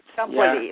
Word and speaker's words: Ciampoli: And so anyway, we pronounce it the French word, Ciampoli, Max Ciampoli.